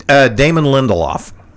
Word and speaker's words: Damon Lindelof: bearing the change of heart by producer Damien Ledefour. Am I saying that right? – Yeah. Damon Lindelof.